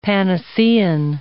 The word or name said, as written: panacean